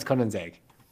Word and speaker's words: Koenigsegg: How do you pronounce KOW-NEEGS-EGG? – KOW-NEEGS-EGG. – Yep. – KOW-NEEGS-EGG.